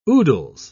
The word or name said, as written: oodles